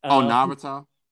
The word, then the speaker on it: Naruto: I'm not a fan of Naruto or anything like that. I don't know if I'm saying that right. – Naruto? – Yeah.